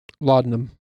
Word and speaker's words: laudanum: Laudanum.